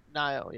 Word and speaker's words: Nioh: Best action game: Prey, Nier, Nioh. – How'd you pronounce it? Nioh, yeah.